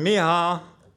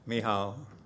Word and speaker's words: Michal: And Michal, that's what it's the real pronunciation is, M-E-H-A-A-L, Michal,